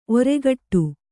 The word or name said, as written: ಒರ್ಗೊಡೆ